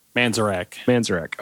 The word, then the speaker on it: Manzarek: Manzarek.